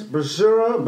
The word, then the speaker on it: basura: Basura. – Basura.